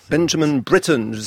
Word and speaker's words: Benjamin Britten: Benjamin Britten, britannique. Donc j'assume la prononciation anglophone.